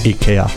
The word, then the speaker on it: IKEA: So, ladies and gentlemen, the Christmas 2019 ad with 52% of the votes cast in favour of it is IKEA. – Very good, very good, yeah. Said it correctly.